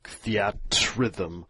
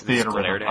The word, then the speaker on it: Theatrhythm: I called it the at rhythm, and then someone got pissed off at me. They're like, no, it's theater rhythm.